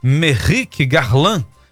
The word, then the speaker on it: Merrick Garland: O movimento de Alcolumbre é inspirado no caso de Merrick Garland. Acho que é assim que pronuncia? Exatamente. Hoje, In...